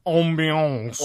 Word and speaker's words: ambiance: That's a French word, I think. – Yeah, that's exactly how you pronounce it, I think. Ambiance.